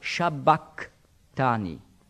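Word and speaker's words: sabachthani: Und darum wurde das im Neuen Testament transkribiert, umgeschrieben mit Sabach, dann kommt ja ein Chi. Und das hat man im Altgriechischen nicht als Ch, wie im Schweizerdeutschen, ausgesprochen, sondern eher als K, also Shabbatani.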